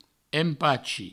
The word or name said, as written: empachi